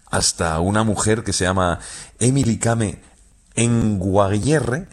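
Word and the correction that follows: Kngwarreye: lo he dicho mal. – Emily Kame. – Con Guarreye. – Emily Kame con Guarreye, sí.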